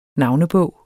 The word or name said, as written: navnebog